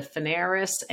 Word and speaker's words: Fanaras: Again, my name is Linda Fanaras, and you can reach me on LinkedIn for sure.